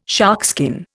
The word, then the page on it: sharkskin: Indian